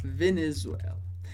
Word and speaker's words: Venezuela: Interessante sobre a pronúncia do nome do país. É mais comum que a gente veja em francês sem o acento. Mas, na verdade, o que a gente diz em francês é Venezuela.